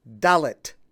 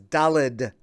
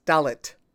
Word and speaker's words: dalet: The name of the fourth letter of the Hebrew alphabet is dalet, with a T at the end. I know many people pronounce it as dalet, with a D at the end, but that's not correct.